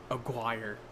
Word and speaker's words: Aguirre: The only Herzog movie I've seen is Aguirre. Is that how you pronounce that? – Aguirre. – Oh my god, I'm such a fucking American sometimes. It's Aguirre.